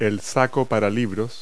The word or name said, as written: bookbag